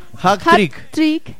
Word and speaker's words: hat-trick: Hat-trick de Miguel Ángel Borja.